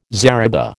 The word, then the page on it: zariba: American English